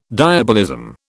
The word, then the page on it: diabolism: British English